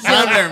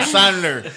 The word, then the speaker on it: Sandler: Iba Yo, el de Yauco diciéndole de calle cómo se pronuncia. Sandler, mijo, Sandler.